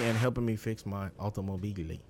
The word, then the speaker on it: Automobili: That's how you say it in Italian, right? Automobili. – Oh, that's never mind. You did say it right.